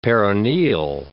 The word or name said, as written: peroneal